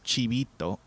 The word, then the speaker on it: Chibito: Like, my name is two words in Japanese. It's Chibi and Hito. It's supposed to be pronounced Chibito, and it means small, little, cute child.